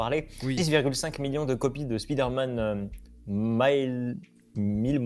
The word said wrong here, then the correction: Miles Morales: Morales. Miles Morales. je crois. Miles Morales. prononcer les S.